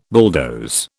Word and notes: bulldoze: British English

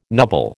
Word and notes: nubble: American English

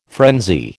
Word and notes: frenzy: American English

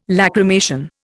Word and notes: lachrymation: Indian

